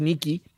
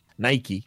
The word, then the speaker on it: Nike: Dice que mala testa que se enamoró de unos playeros Nike, Nike, que se dice así, mm.